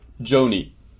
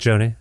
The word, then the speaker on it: Joni: No, do you pronounce it Joni or Johnny? – Joni. – Joni, yeah, like Joni Mitchell?